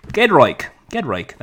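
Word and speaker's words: Giedroyc: She hosted the Great British Bake Off with her comedy duo partner, Mel Gedroik. That's how you pronounce it.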